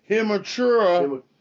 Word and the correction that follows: Hachimura: from Washington just made a poster on Tobias Harris, ladies and gentlemen. Rui Hachimura is what I'm trying to pronounce, what I saw on my TV.